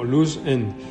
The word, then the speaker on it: Loozend: Eh, ah, eh, sí, en, inglés es como Luz End, se escribe L-O-O-Z-E-N-D.